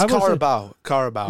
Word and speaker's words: Carabao: It's Carabao. – Is it Carabao? Carabao. – I say Carabao Cup. – Carabao. B-A-L.